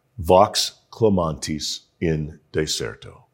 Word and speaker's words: Vox clamantis in deserto: If you're an insufferable Latin purist like me, you know that V's are pronounced W's in Latin, but that's too confusing for folks. So we're going to go Italian Latin and please repeat after me.